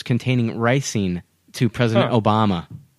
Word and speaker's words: ricin: But there's a poisonous toxin called ricin. Is it ricin? – Yeah.